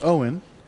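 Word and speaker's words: Eoin: Now, Owen, so it's E-O-I-N, pronounced Owen.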